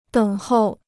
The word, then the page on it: 等候: děng hòu